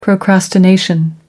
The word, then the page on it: procrastination: American English